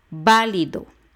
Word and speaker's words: válido: Válido. También son tres sílabas y de derecha a izquierda. La última es do, la penúltima es li y la antepenúltima es va, válido.